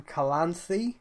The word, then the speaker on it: Calanthe: Ciri is the granddaughter of Queen Calanthe.